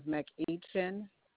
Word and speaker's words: McEachin: Yes, the first name is Yvette, Y-V-E-T-T-E. Last name is McEachin. – Can you spell that last name, ma'am? – M-C-E-A-C-H-I-N.